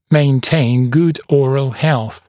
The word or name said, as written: maintain good oral health